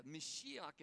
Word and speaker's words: Mashiach: Messiah, Mashiach, as it's pronounced in Hebrew, simply means the anointed one.